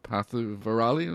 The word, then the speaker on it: Pantuvarali: You know, based on a traditional raga called uh, Pantuvarali. Am I saying that right? Uh, yep.